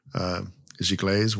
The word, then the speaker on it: Giclée: Giclées weren't really popular yet. That's spelled kind of weird. It's a French word, G-I-C-L-E-E. – Makes me uncomfortable. Pronounced Please Giclée. Don't say it again.